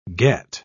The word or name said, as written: get